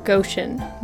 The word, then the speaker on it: Goshen: Goshen, not Goshen. Go like go, not gosh golly.